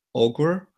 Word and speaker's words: augur: There is a word in, um, in English, um, A-U-G-U-R, I don't know how to pronounce it, I think it's augur,